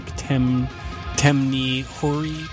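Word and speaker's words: Temné Hory: The anglicized pronunciation would be something like Temni Hori, but these are Czechian words, so that's definitely butchered. T-E-M-N-E-H-O-R-Y.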